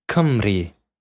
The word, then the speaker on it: Cymru: C-Y-M-R-U, pronounced Cymru. – Wow. Oh my goodness. That was excellent. – How epic Cymru.